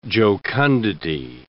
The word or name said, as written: jocundity